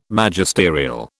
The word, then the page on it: Magisterial: British English